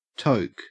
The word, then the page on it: toke: Australian English